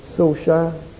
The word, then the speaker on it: Solskjaer: Sole Gunnar Solskjaer.